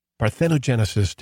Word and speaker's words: parthenogenesis: So let's begin by defining the word parthenogenesis.